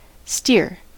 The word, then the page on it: steer: American English